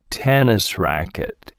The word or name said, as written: tennis racket